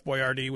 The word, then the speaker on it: Boiardi: Because he actually spelled his name as B-O-I-A-R-D-I. That, and it's pronounced Boyardee. – Mm. – But they changed it to B-O-Y-A-R-D-E-E so that, like, kids could, like, pronounce it and recognize it.